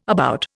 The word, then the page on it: about: Indian